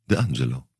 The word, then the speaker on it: D'Angelo: Also wie gesagt, Neustart bei Mielke unter Casa d'Angelo. D'Angelo. – Das ist kein Franzose. – Kann man mal hingehen. Wie spricht man Italienisch aus dann? – Der Angelo. – Der Angelo. – So muss man das sagen.